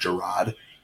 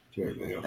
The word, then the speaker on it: Gerard: He's like, it's actually pronounced Gerard, so it's Gerard Mayo. – Jared Mayo. – I mean, either way. – His entire NFL career, everybody called him Jared Mayo.